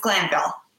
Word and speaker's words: Glanville: Is it Glanville? I'm the worst at pronouncing words. I'm the same. Um, yes, it's Glanville.